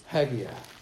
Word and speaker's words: Haggai: Turn your Bibles to Haggai. It's pronounced in different ways, but that's the way I pronounce it.